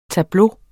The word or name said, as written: tableau